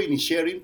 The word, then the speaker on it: Inisherin: Yo digo Inisherin porque supuestamente es lo más cercano al gaélico, pero Rafa Sarmiento, claro, dice Inisherin, que también puede ser más cercano al gaélico.